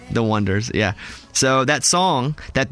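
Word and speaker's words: Oneders: The Wonders, and they first spelled Wonders O-N-E-ders, mm-hmm. like with the number one, and people were pronouncing it, oh, here they are, the O-N-E-ders.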